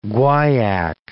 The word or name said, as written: guaiac